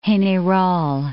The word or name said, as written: General San Martin